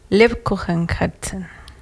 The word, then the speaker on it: Lebkuchenherzen: Lebkuchenherzen.